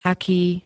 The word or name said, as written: aký